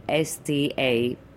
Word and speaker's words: Staphylos: There is Staphylus red and white, and that would be Staphylos. Okay. And it means grape, actually, Staphylos. Oh. Staphyli in Greek.